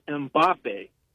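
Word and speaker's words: Mbappé: His name's last name is, you say it, Mbappe. That's how you pronounce his last.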